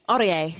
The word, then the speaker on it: Aurier: So keep an eye out for him, Serge Aurier, and I hope that's how you say it. Although you've got the French connections, Aurier. Aurier, mm.